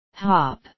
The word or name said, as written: hop